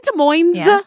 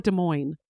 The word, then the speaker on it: Des Moines: Is it Des Moines or Des Moines? – It is not. The S is silent.